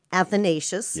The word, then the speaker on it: Athanasius: Todd, you mentioned today is the feast day of St. Athanasius.